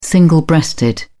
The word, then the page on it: single breasted: British English